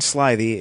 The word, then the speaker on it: slithy: The I in Slithy is long, as in writhe, and Toves is pronounced as to rhyme with groves.